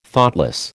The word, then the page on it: Thoughtless: American English